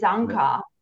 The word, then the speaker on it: Anka: So if you check my passport, it's Anka. But my American friends, I have double citizenship. So everyone had such a hard time pronouncing it.